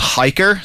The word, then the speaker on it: Hiiker: As far as navigation, there's an app that I would absolutely swear by, and it's called Hiker. So it's spelled H-I-I-K-E-R.